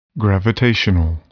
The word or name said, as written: gravitational